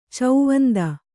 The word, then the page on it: ಚೌವಂದ: kannada